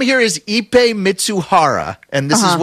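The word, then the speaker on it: Ippei Mizuhara: There's a phonetic pronunciation, Ipe Mitsuhara.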